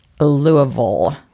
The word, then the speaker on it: Louisville: Several Kentucky residents messaged us like I knew they would, and they said that it is not Louisville or Louisville. It's pronounced, let me see if I can get this right, Louisville.